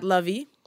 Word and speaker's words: Luvvie: Yep, and I am at Lovey, L-U-V-V-I-E, because people constantly spell my name wrong.